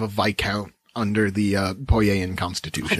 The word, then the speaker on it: viscount: Oh, that's how that's pronounced. Yes. I've only seen that word written down. – Viscount? – I've always thought there was that. Wow. – Oh, yes. Like, never mind. It's like vis-a-vis. Nope.